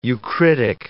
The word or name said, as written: eucritic